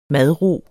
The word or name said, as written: madro